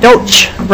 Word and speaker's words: Doach: Representative Saylor, you and the Superintendent Doach, rhymes with coach, I put down here so I can remember it,